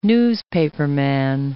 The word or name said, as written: newspaperman